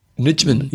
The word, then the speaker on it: Nijman: Good old Nicholas Nijman. No, how do you say that one? N-I-J. Nijman. – Yep. – Nijman, maybe? – Yep.